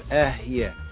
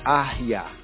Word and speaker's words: Ehye: Now, some latter-day Hebrews and Israelites, they mispronounce it. They call it correct, but it's really incorrect if we study Hebrew more comprehensively, right? But it's baby Hebrew, all the same. They will say it's Ahya, Ahya.